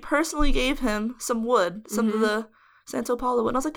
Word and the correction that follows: Palo Santo: Palo Santo.